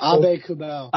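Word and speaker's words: Aube-Kubel: Darcy Kemper, Nazem Kadri, Andrei Burakovsky, Jack Johnson, Ryan Murray, and Nicholas Abe Kubel? I can't remember how to say his name. – Abe Kubel.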